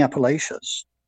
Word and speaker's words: Appalachians: It was like in the Appalachians.